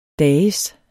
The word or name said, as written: dages